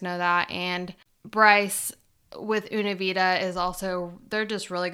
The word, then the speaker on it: Una Vida: He is the co-owner of Una Vida Tequila.